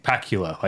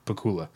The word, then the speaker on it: Pakula: So what I was going to say is Tyler and I like to pronounce his name Pacula, like Dracula. Alan J. Pacula, but I'm pretty sure it's pronounced Pacula.